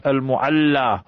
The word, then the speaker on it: Al-Mu'alla: and we make dua there by the graveyard Al-Mu'alla. And that is the proper pronunciation.